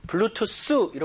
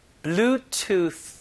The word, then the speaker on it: Bluetooth: 자, 요 발음이 TH 때문에 블루투스 이렇게 하는 건 조금 영어에서 많이 벗어나고요. Bluetooth 하면서 혀끝을 윗니, 아랫니 사이에 넣으면서 공기를 막아야 돼요. 자, Bluetooth. 느낌 와요?